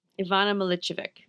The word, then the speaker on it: Milicevic: I looked up how to pronounce her name, but now it's out of my head. Milicevic? – Ivana Milicevic. – Yes.